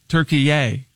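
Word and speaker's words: Türkiye: So he immediately demanded that it be pronounced Turkiye. Turkiye. Turkiye.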